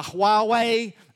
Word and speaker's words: Huawei: Uh, LG, uh, Oppo, uh, Huawei, um, which, uh, thank you, I pronounced that correctly.